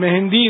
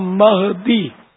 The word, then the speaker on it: مہدی: رمضان تلفظ غلط ہے, رمضان میم پر زبر ہے, اور امام مہندی نہیں ہے. مہندی تو ہم ہاتھ میں لگاتے ہیں,